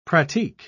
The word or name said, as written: pratique